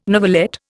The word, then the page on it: novelette: Indian